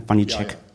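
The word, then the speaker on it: Vaniček: Richtig ausgesprochen, ja, ne? Vanitschek. Ja, sagt Chris.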